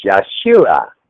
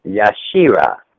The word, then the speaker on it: Yeshua: The Hebrew pronunciation of Jesus is Yeshua, Yeshua, amen.